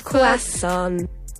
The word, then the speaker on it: croissant: But yes, croissant. It's French, number 1. Do you pronounce the R or not? Is it croissant? – Croissant. Yeah.